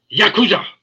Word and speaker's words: Yakuza: And it's from a standpoint of, how you think of the Yakuza and the martial arts and the Dennis, you're not saying it right. It's Yakuza. It's the Yakuza.